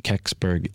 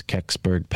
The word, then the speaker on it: Kecksburg: If anyone looks it up on Wikipedia, it's spelled K-E-C-K-S-B-U-R-G. Incident, Keksberg incident, and that's Keksberg, Pennsylvania.